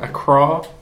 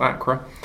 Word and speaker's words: Accra: Ellie was there in Accra, I understand it's pronounced, not Accra, for our reporting team.